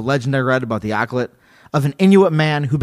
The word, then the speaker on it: Akhlut: It's spelled A-K-H-L-U-T and pronounced like Akhlut or, yeah, Akhlut, Akhlut.